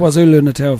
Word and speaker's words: KwaZulu-Natal: Oh, I say, you've been lead vocalist with the Cape Town, and let's see if I can pronounce KwaZulu. Yeah. Natal Philharmonic Orchestra. – Yes, KwaZulu-Natal Philharmonic Orchestra.